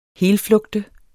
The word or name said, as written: helflugte